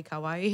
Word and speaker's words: kawaii: I always say it wrong, like Hawaii, kawaii.